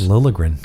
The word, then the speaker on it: Liljegren: Liljegren.